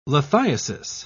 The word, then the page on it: lithiasis: British English